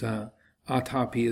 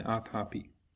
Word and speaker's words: atapi: So it's atapi is a long A, T, long A, P, I, atapi.